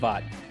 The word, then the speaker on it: Vogt: Yeah, Brandon Vaught. Just my name. – Brandon Vaught. And that last name is spelled V-O-G-T. Vogt. Vogt.